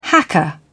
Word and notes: hacker: British English

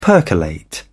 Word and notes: percolate: British English

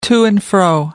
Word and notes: to-and-fro: American English

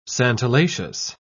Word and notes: santalaceous: British English

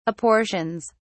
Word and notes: apportions: British English

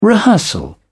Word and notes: rehearsal: British English

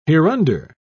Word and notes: hereunder: British English

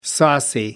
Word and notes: saucy: American English

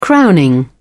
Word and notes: crowning: British English

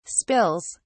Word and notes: spills: British English

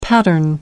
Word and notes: pattern: American English

